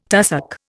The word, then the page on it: tussock: Indian